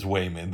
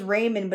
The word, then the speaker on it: Waymond: And by the way, they pronounce it as Waymond. That's not us trying to make fun of Chinese. – It sounds... people. I thought it was Raymond, but it's supposed to be Waymond.